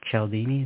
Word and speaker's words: Cialdini: Robert Cialdini, is that how you pronounce it? – Yeah.